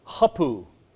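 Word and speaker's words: hapu (ὅπου): Who, and now here's another new word, hapu. Always rough breathing, accent on the first syllable, means where.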